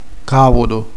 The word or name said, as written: cavallo